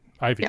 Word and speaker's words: Ivey: The co-founder of Studio Studio, a modern venue and co-working space for creatives and photographers, and partner at, is it Ivey? – Ivey. – Yep. It's spelled I-V-E-Y, that's why I asked.